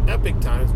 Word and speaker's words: Epoch: You can check that out at Epoch Times, or they call themselves Epic Times, but it's spelled E-P-O-C-H.